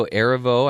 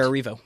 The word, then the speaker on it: Erivo: Lead actress Cynthia Erivo, I think is how you say her name for Harriet. Cynthia Erivo.